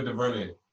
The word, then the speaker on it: DuVernay: She like gathered all like the powerful um black moguls like in the movie industry, like the Anna Anna DeVere. What how do you say her last name? Ava DuVernay.